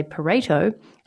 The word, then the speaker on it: Pareto: Even though it's spelled P-A-R-E-T-O, and a lot of people say Pareto, it's Pareto.